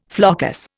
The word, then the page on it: floccus: Indian